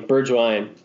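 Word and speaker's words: Bergwijn: So, how do you say his name? Bergwin. Yes, wine, Bergwine, Bergwine, Bergwine, Bergwine.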